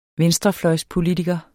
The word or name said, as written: venstrefløjspolitiker